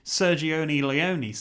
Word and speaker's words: Sergio Leone: And even when it came out at the time, Sergio Leone saw it.